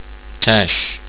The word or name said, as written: tash